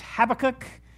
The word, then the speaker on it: Habakkuk: Unless you went to Jesse's School of Pronunciation, then it's Habakkuk. I will not be able to say it that way. I've been saying Habakkuk since I was like 5 years old.